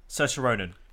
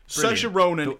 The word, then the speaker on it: Saoirse Ronan: Saoirse Ronan. Saoirse Ronan is the saving grace of that whole movie.